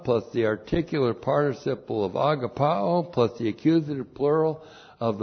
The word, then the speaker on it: agapao: A, G, A, P, A, long O, agapao.